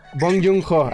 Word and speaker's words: Bong Joon-ho: Eh, a ver, dime si lo pronuncio bien. Bong Joon-ho la hace. Correcto. Este. Bong Joon-ho, ajá.